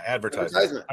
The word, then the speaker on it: advertisement: You're putting the wrong emphasis on the wrong syllable. Like, fuck. – How do you say it? – Like people who say advertisement. – Yeah, advertisement. – I would say advertisement.